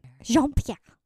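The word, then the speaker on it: Jean-Pierre: Karine Jean Pierre.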